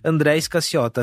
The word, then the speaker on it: André Scaciota: E eu, como host aqui, tenho essa preocupação de pronunciar corretamente, mas André Escassiota, assim como se escreve, head de mídia do Itaú Unibanco, bem-vindo, obrigado, cara, pelo tempo e por estar aqui com a gente.